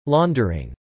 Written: laundering

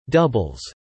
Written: doubles

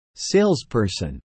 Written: Salesperson

